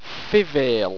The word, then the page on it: fivale: Owstwecian